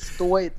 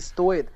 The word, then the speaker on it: стоит: Так вот, да, он постоянно оценивает, стоит, не стоит. Кстати, вот другое ударение, в смысле стоит, не стоит, наверное, даже было бы точнее.